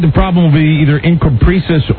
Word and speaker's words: encopresis: You need to pronounce the name correctly. – Did I get that wrong? How do you pronounce that? – It's Encopresis. – Encopresis.